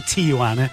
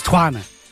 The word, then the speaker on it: Tijuana: There's obviously more than one trumpeter in the Tijuana band. Well, it's not called Tijuana, it's Tijuana.